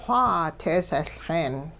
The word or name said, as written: xwaa teesehLxeen